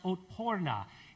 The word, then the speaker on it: otporna: This is a word in Serbian, which is pronounced otporna, and it means hard to kill, or bulletproof, or resilient.